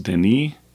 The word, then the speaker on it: Denis: And I heard his name pronounced Denis. It's D-E-N-I-S.